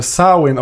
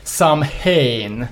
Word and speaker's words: Samhain: Eh, tre olika originaluttal. Det är ju Sowin, Sowin och Sowin. Eh, på de olika dialekterna då. Eh, och Danzig tröttnade väl på att det var ingen jävel som kunde uttala det. Så då amerikanskade amerikanskan det till Samhain.